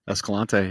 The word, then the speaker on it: Escalante: Did I say that right? – Escalante, yeah.